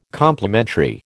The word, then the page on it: Complementary: American English